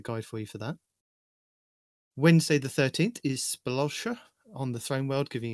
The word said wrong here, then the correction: Sepulchre: No. Sepulchre is how you say it, but how did you say that? – Spelosha.